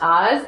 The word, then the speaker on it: Az: But anyway, the Aramaic word is pronounced Az, A-Z, and it's used in John 13:21.